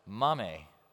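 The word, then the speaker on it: mame (μάμμη): And I think this is kind of interesting because the word for grandmother here in this ancient Greek is actually pronounced mame.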